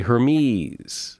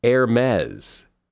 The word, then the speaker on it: Hermès: A lot of people say Hermès. I've always said Hermès. It's actually Hermès, as in Pez.